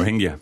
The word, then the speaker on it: Rohingya: I can't pronounce that suddenly. – Rohingya. – Thank you.